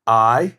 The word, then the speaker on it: Ai: Remember Ai? It's spelled AI, not like artificial intelligence, but it's pronounced Ai.